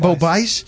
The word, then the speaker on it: Bo Bice: Is that how you pronounce his name? Bo Bice? – Bo Bice.